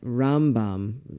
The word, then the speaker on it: Rambam: Rambam, emphasis on the first syllable when talking about Maimonides.